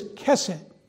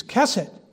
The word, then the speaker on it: chesed: Mercy's a minor part of this word, but the word is chesed. I can't even pronounce it like you should because it should sound like you're spitting your mouth out with a phlegm in your mouth when it's a hard kuh. And it's chesed.